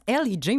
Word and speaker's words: L.E.J: Maurice, tu me dis que ça se prononce Ellie J, mais est-ce qu'on peut savoir pourquoi un groupe français ne se nommerait pas L.E.J., mettons? – C'était la mode.